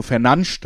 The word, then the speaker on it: Fernandes: Aber wichtigste Spieler natürlich äh, Bruno Fernandes oder Bruno Fernandes, das kann sich jeder selber aussuchen. Das wäre die korrekte Aussprache, habe ich gelernt.